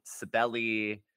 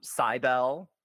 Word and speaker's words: Cybele: And speaking of things that are, you know, questionably pronounced, I'm actually talking about Cybele today. Maybe sometimes Cybele. One person said Cybele. I don't like that one.